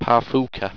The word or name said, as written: parfuka